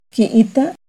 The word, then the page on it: Quiitta: Náhuatl